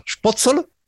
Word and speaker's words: Spotzel: Et en fait, Spotzel, en alsacien, ça se prononce Spotzel, ça avec un accent germanique.